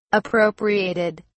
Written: appropriated

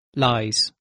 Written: lies

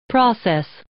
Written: process

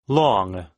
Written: long

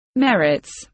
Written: merits

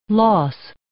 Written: loss

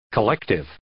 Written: collective

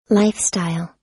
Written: lifestyle

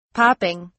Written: popping

